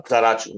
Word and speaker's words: Zaracho: É Zaracho ou Zararro? Sei lá como se fala. – Zaracho, é. Zaracho, Zaracho. Né?